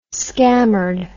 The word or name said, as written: scammered